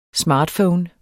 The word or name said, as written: smartphone